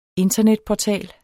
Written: internetportal

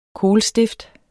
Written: kohlstift

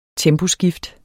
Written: temposkift

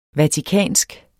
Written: vatikansk

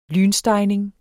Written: lynstegning

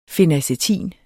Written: fenacetin

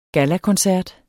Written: gallakoncert

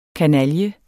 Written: kanalje

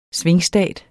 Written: svingstat

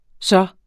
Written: så

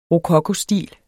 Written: rokokostil

